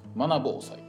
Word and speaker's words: マナ防災: で、読み方はそのままスッと読みます。マナ防災です。途中で変なとこで切らんようにしてください。